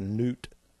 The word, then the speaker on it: Nut: There's Nut. That's how the Egyptians saw the firmament. Well, I'm sure it's pronounced Newt, but I call it Nut, N-U-T.